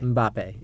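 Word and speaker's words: Mbappé: Yeah, Mbappe. – Mbappe, yeah.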